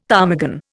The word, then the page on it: ptarmigan: Indian